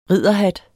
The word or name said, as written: ridderhat